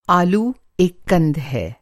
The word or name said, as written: कंद